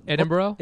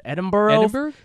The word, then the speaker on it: Edinburgh: Edinburgh? – Isn't it Burrow? – Yeah. – But the spelling is weird. Edinburgh.